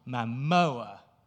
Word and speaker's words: Momoa: I thought to play Ganon, the King of Thieves, we can have Aquaman himself, Jason Momoa.